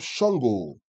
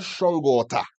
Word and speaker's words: Shango: there's something that is called Shango. Emphasis on the go. Shango. Yeah? Not Shangota. Shango. Shango. Yeah?